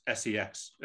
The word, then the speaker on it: CeX: It's called CEX, Computer Entertainment Exchange, but they pronounce it as sex because they're trying to be a little bit edgy.